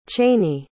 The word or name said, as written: chaney